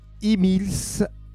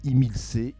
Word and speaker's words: Himilce: Donc je vous rappelle le titre Imils, Emils, Imils, suivant les prononciations.